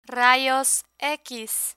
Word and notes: rayos x: spanish

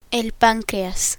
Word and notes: pancreas: Spanish

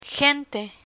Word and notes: gente: Spanish

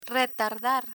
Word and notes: retardar: Spanish

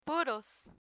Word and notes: puros: Spanish

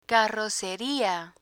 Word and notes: carroceria: spanish

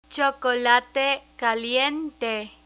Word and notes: chocolate caliente: spanish

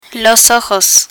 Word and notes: ojos: spanish

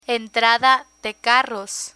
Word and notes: entrada de carros: Spanish

